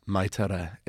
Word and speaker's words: Magh Tuireadh: but having consulted with Irish speaker friends, Mai Tuireadh is the pronunciation that I will hopefully stick with from here on out.